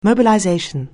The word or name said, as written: mobilization